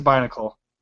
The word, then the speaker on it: Binacle: Yeah, it is Binnacle. – How do you spell that? – B-I-N-A-C-L-E. – Is wow. Just like Bionicle.